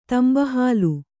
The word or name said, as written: ತಂಬಹಾಲು